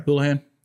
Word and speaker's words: Hullihan: Have I always said that right or wrong? You said it right. Hullihan. Yeah. It's H U L L, not H O U.